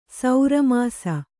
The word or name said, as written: ಸೌರಮಾಸ